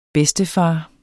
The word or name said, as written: bedstefar